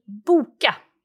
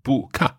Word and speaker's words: boka: B-O-K-A. – Right. Okay. Boka. So it's that oo sound for the single O that sometimes confuses me.